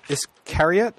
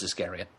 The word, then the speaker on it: Iscariot: I'm up on page 300 for Iscariot. I think that's how you'd pronounce that. – Yep, as in Judas Iscariot.